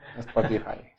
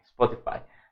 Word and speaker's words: Spotify: Ahora sí lo pronuncio bien, señor productor. Spotify. Spotify. Los esperamos el lunes,